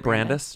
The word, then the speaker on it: Brandis: age, whatever, but I thought he looked like Jonathan Brandeis. I don't know if that's... Is that how you pronounce Brandis? – Isn't it Brandis? Just Brandis? – Yeah. Sorry. Mm-hmm. Not like the college.